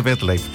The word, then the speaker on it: MetLife: Y seguro salud total de MetLife. Sí, lo dije bien. Seguro salud total de MetLife.